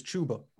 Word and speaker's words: Chuba: 1B is Chuba, whatever. – Like a tuba. And I'm unfortunately, I think that's the correct pronunciation, but that doesn't matter.